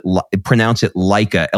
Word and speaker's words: Leica: And he used a camera called, I think you pronounce it Leica, L-E-I-C-A, Q2.